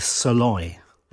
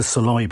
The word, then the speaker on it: Szalay: Well, I'm going to go with Saloy. – Okay. – Shall we spell it? – Yes, S-Z-A-L-A-Y. – Yeah, he's a British-Hungarian author, so I assume the Saloy bit is more Hungarian bit.